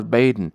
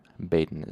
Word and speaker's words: Baedn: I told you for months now, you, you can't go out alone, not as, even as far as Baedn. It ain't safe. Now, Baedn is spelled B A E D N. It's like Bay and then D N.